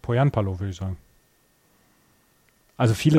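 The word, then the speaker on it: Pohjanpalo: Ich habe irgendwann mal gehört, dass die Finnen auf der ersten Silbe immer betonen. Dann hieß es Poyanpalo.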